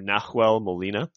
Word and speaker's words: Nahuel Molina: So what does Messi do is he drives in that area and lays off a ball to Nahuel Molina, I think is his name. So correct me if I'm mispronouncing right back. – That. – Yep. Nope, you're good.